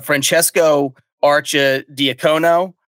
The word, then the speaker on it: Francesco Archidiacono: So the artist is Francesco Arcia Diacono, if I'm saying that correctly. – You said that beautifully.